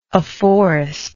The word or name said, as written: afforestment